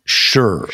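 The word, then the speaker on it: shih: Now, it's spelled S-H-I-H, I believe. – It is. That's the Chinese, right. Transliteration in English, but it is pronounced sure.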